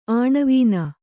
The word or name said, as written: ಆಣವೀನ